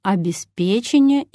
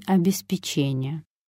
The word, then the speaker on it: обеспечение: То же самое произойдет со словом «обеспечение» и «обеспечение».